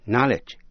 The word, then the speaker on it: knowledge: K-N-O-W-L-E-D-G-E-A-B-L-E, knowledgeable. Tính từ có nghĩa là hiểu biết nhiều, am tường vấn đề. Để ý lại cách phát âm của ba chữ know, động từ, danh từ knowledge, tính từ knowledgeable. Quý vị nghe lại, know, knowledge, knowledgeable. Quý vị sẽ nghe tính từ final, F-I-N-A-L, final là cuối cùng.